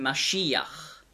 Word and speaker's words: Mashiach: Also die Juden sagen Mashiach, der Gesalbte. Und dann die griechische Aussprache dieses hebräischen Wortes ist Messias.